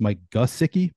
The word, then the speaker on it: Mike Gesicki: I didn't realize, I've always said Mike Gesicki. I'm seeing here it's Mike Gesicki for the Dolphins.